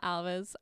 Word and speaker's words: Alves: My name is Emma Alves.